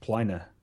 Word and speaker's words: Pliny: The original Roman would be pronounced Plina. Plina the Elder.